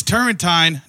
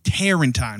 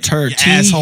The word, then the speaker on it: Turrentine: It's Turrentine, not Tarentine. – Yeah, right? – Turrentine. Turrentine. Tur. Like turned up or something.